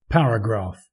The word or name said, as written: paragraph